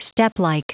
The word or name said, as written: steplike